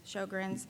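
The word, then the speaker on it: Sjögren's: And also during that time, I was diagnosed with Sjogren's syndrome. Some people pronounce it Sjogren's.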